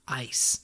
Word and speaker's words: Ais: I've been saying it Ice. – A-I-S. Ice.